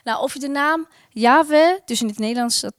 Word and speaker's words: Yahweh: Maar wat we wel weten is dat voor de Joden deze naam eh, zo heilig was dat ze het uit eerbid niet wilden uitspreken. En het staat wel algemeen bekend als Yahweh, dus zo zal ik het ook zeggen.